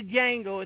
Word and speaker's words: Django: The D is silent. This is it playing right now. Ode to Django.